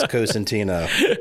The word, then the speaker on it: Cosentino: And thanks for pronouncing my name right there, Ben. I appreciate that. It's Cosentino.